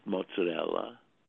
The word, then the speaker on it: mozzarella: Mozzarella, it has an A at the end.